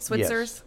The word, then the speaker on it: Switzer's: Switzer's? – Yes.